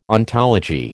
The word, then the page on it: ontology: American English